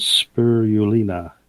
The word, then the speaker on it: spirulina: The fourth one is spirulina. I'm pronouncing S-P-I-R-U-L-I-N-A.